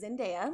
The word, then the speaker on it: Zendaya: I've been in love with Zac Efron for years, and Zendaya, and I did look up how to pronounce her name, it is Zendaya.